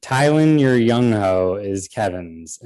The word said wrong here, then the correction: Thielen: and that is you got to pretend it's pronounced more Thielen. Thielen, your young ho.